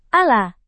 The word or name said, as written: ala